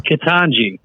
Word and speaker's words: Ketanji: Ketanji. – That's Ketanji. The one. Ketanji Brown Jackson, actually, not Jackson Brown. I'm thinking of the musical group. Ketanji Brown Jackson, who has made very clear by her judicial decisions that those that molest children should not receive significant penalties.